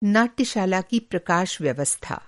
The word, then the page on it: नाट्यशाला: Hindi